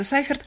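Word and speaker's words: Seifert: Ярослав Сейферт, по-чешски фамилия произносится Сайферт, родился 23 сентября 1901 года в Жишкове.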